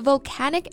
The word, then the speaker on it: volcanic: And we should be careful with this pronunciation. Volcano 名词的时候，中间元音是 a，volcano 形容词呢变成 volcanic，元音变成梅花音 a，volcanic. Because we'll be using this word a lot in the podcast. We should know how to say it correctly.